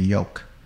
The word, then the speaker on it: yolk: The yellow part inside the egg, if you crack the egg open, the yellow part is called the yolk, Y-O-L-K. The L is silent.